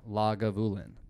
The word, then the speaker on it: Lagavulin: Did you know that Lagavulin is like one of the very few Scotch distilleries that is actually pronounced the way it looks like it's pronounced. Like a lot of them are wild and shit, but that one actually, you just read it like it looks.